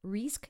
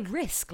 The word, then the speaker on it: Rizq: R-I-Z-Q? Rizk? I didn't know how to say it. – Rizk? – Well, I didn't want to say Risk, like the board game.